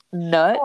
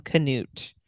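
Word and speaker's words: Knut: It's funny that you say that though, because that was like one of the things, like she kept being like, Nut. Is that how you pronounce her husband's name? – So Nut? That's K-N-U-T. That's how it's spelled. In the audio, they called him Canute.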